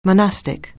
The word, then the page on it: monastic: anglais